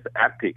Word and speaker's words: Epoch: If you go to our newspaper, Epoch Times, E-P-O-C-H, someone pronouncing as Epoch, some people pronouncing as Epic. It's just a Canadian way or American way to pronouncing that word.